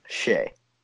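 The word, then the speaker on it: Skjei: How do you say his last name? – Shea. – Brady Shea.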